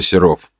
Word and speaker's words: Серов: Целью нашего задания было исследование города Серов. Ударение на О.